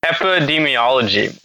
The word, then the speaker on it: epidemiology: Epidemiology.